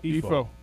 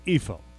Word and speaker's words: Ifo: Okay, phonetic spelling. Okay, EE-FO. Can you pronounce that? – EFO. – Okay, EFO. Okay.